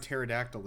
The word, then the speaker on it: pterodactyl: The P in the word pterodactyl is silent.